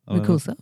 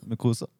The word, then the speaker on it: Mukosa: Ja, nå kommer vi jo til det prosjektet som du jobber på nå, som vi eh, sliter litt med å uttale, som er 3D eh, Bronchial Mukasa. – Mukosa. Mukosa. Eh, mm, riktig.